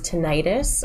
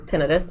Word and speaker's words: tinnitus: Um, this is tinnitus or tinnitus, depending on how you pronounce it.